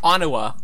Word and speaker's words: Onua: Onua. That makes sense. The Turaga of stone, Onua. And it makes sense for the same reason that Lua makes sense. It's like that E-W-U sound effect. It's like Onua, Onua. It's just you didn't put as much emphasis on it. So, like, Onua, there's more stress on the U.